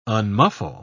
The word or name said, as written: unmuffle